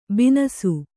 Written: ಬನಸಿ

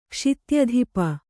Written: ಕ್ಷಿತ್ಯಧಿಪ

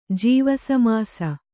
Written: ಜೀವಸಮಾಸ

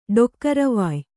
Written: ಡೊಕ್ಕರವಾಯ್

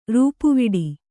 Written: ರೂಪುವಡೆ

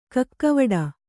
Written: ಕಕ್ಕಾವಾಡೆ

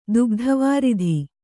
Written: ದುಗ್ಧವಾರಿಧಿ

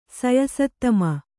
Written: ಸಯಸತ್ತಮ

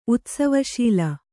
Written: ಉತ್ಸವಶೀಲೆ

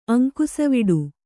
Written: ಅಂಕುಸವಿಡು

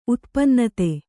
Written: ಉತ್ಪನ್ನತೆ

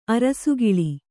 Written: ಅರಸುಗುಲ

